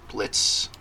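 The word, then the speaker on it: Blitzø: First, we have Blitz. B-L-I-T-Z-O, but the O is silent.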